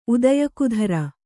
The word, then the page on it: ಉದ್ಯೋಗದಾರ: Kannada